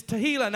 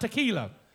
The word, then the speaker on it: Tehillah: Now, pronounce it rightly, is Tehillah, not tequila.